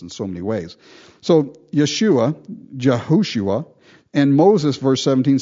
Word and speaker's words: Yeshua: It's Yeshua. in the Hebrew, that's the way they pronounce it. They don't pronounce it Joshua like we do. They pronounce it Yeshua, Jesus, same name.